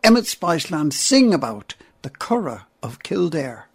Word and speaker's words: Curragh: The Curra, which is spelled C-U-R-R-A-G-H, is a flatland south of Dublin in County Kildare.